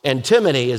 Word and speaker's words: antinomy: but it's spelled A-N-T-I-N-O-M-Y, and it's pronounced many different ways when you hear it, but antimony is the way I pronounce it.